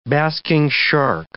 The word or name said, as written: basking shark